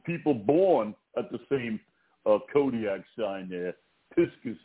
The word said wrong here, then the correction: Pisces: Is that how you say it, Vince? Piscus? – Yeah, I think it's called Pisces. – Oh, Pisces. That right.